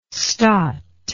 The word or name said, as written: stot